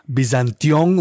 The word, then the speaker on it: Byzantion: How is it that we're supposed to be pronouncing Byzantion, Byzantion, Byzantion? Like, how do we, what are we, from the horse's mouth, we want to know. – I mean, we call it Byzantion.